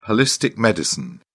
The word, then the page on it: holistic medicine: British English